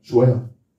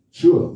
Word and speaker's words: Sheol: What's the right pronunciation? Sheol. Sheol. You get the word S-H-E-O-L.